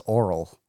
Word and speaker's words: aural: I think it's just pronounced oral. – It's pronounced oral? – I think so.